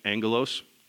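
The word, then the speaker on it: angelos (ἄγγελος): The Greek word that is translated into angel is the word, and it's actually pronounced the same no matter how you spell it. It's angelos, okay?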